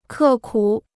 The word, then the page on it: 刻苦: kè kǔ